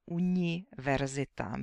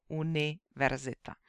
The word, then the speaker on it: univerzita: Co se týče výslovnosti, tak jediná chyba, která mě asi nejvíce zaujala nebo které jsem si nejvíc všímala, byla ta, že u slova univerzita nebo u některých takzvaných cizích slov, u slov, která nejsou českého původu, to vyslovovala měkce. Říkala univerzita místo univerzita.